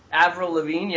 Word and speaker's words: Avril Lavigne: You, Avril Lavigne makes five wishes you got in there. Is that how you say it? – Avril Lavigne, yeah.